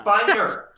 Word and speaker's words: Spiner: But the first thing that is popping to my head is just Brett Spinner. We need to talk about this guy. – Spiner. – So Spiner. – Spiner? That's how you say it? – Yeah.